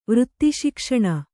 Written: ವೃತ್ತಿಶಿಕ್ಷಣ

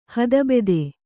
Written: ಹೇತುಭೂತ

